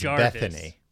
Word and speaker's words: Bettany: It's pronounced Bethany.